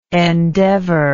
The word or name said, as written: endeavorer